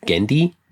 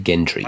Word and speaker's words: Genndy: I actually have more trouble with Genndy because I want to throw an R in there. I want to say Gendry.